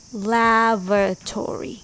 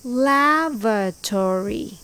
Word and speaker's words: lavatory: Lavatory. It's pronounced lav-a-tory. It's very similar. The stress is on the first syllable, lav, with V sound. V.